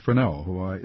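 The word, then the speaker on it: Freneau: Benjamin Franklin, Thomas Jefferson, Ethan Allen, Thomas Paine, Elihu Palmer, and Philip Fresnel. Am I saying his name right? – Absolutely.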